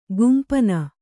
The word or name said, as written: ಖಂಪಣ